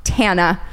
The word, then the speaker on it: Tana: If you know that tidbit of information, then you know that it is pronounced Tana.